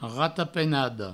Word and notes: ratapenada: ratapenado; rato-penado

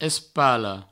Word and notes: espala: provençal; espalo

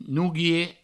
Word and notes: nouguier: nouguie

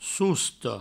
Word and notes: sosta: sousto

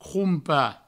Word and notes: crompar: croumpa

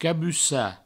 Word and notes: cabussar: cabussa

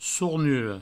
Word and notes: sornura: sournuro

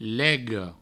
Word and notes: lega: provençal; lego

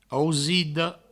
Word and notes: ausida: ausido; Provençal

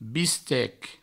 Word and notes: bistec: Provençal; biste

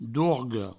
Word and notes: dorga: dourgo; Provençal

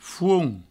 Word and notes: font: fouont

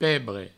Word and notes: pebre: provencal

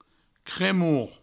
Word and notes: cremor: cremour

